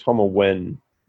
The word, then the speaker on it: Tomiwin: And from what I've heard, it's pronounced Tomiwin, like more of an EN towards the end. – Okay. Could be wrong. So nothing to do with it, with the actual spelling of the word. It's pronounced entirely different.